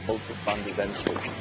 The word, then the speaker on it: Botic van de Zandschulp: Botic van de Zandschulp.